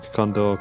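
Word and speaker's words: Kondor: My name is Luke Condor, Condor spelled with K,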